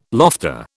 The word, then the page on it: lofter: British English